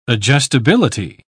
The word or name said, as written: adjustability